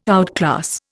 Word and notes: outclass: Indian

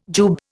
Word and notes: jube: Indian

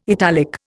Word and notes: italic: Indian

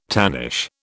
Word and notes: tannish: British English

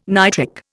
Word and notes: nitric: Indian

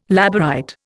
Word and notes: Labourite: Indian

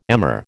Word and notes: emmer: American English

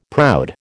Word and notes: proud: American English

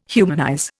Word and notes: humanize: Indian

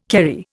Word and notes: Kerry: Indian

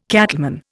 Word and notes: cattleman: Indian

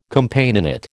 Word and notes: Companionate: American English

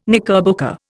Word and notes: knickerbocker: Indian

